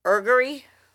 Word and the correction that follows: augury: Augury? – Augury, okay.